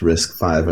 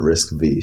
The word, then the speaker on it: RISC-V: I do know that it's pronounced RISC-V and not RISC-V, so I'm there.